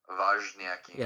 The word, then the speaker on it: Wozniacki: Wozniacki.